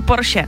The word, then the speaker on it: Porsche: Všichni říkají Porsche špatně, ale je to opravdu Porsche, takže tady si můžeme jako poklepat na rameno, že to říkáme dobře.